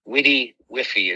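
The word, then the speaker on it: WDYWFY: Witty Wiffy is how that's pronounced.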